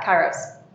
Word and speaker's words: kairos: Yeah, kairos.